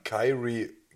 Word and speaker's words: Kyrie: ich denke dann mit dem Irie, wie spricht man aus? – Äh, Kyrie. Kyrie.